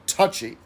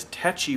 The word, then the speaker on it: tetchy: but because of her accent, she's probably saying the word that everyone else mispronounces it as, touchy, T-O-U-C-H-Y. – I thought it was always touchy, and yeah. I didn't realize tetchy was an actual word.